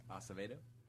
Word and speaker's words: Acevedo: Acevedo. – How do you spell that? – A-C-E-V-E-D-O.